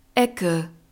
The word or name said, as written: ecke